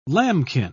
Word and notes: lambkin: British English

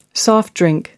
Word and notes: soft drink: American English

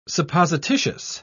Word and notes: supposititious: British English

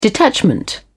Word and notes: detachment: British English

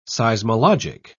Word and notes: seismologic: British English

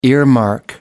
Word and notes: earmark: American English

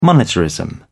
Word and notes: monetarism: British English

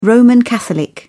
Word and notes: roman catholic: British English

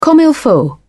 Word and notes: comme il faut: British English

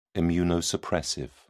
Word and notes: immunosuppressive: British English

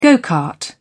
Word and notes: go-kart: British English

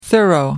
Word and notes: thorough: American English

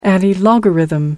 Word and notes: antilogarithm: American English

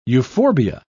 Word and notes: euphorbia: British English

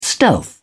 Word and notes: stealth: British English

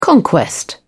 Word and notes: conquest: British English

